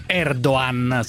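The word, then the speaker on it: Erdoğan: Da qualche parte non si legge la G in realtà, lo diciamo Erdogan, ma in realtà è Erdogan senza pronunciare.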